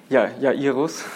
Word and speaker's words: Jairus: He's agreed to go with Jairus, or Jairus, or how do you say it in German? – Jairus. – Jairus.